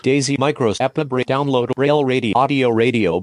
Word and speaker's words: EPUB: The text-to-speech engine I'm using is mispronouncing that. That is the EPUB format.